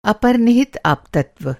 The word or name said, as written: अपनिहित